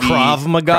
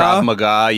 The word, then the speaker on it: Krav Maga: We're talking Krav Maga. Is that how it's pronounced? – Karate. – Krav Maga. – Krav Maga. Yeah, the Israeli self-defense system.